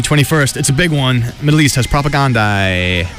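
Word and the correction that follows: Propagandhi: or I totally blew that name. – No one knows that pronounced. – Propagandi, Iron Chick, and Heart Sounds.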